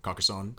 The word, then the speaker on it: Carcassonne: How do you pronounce it? – Carcassonne. – Carcassonne. You sound a little more, I don't know. – French? – Yeah, a little more French.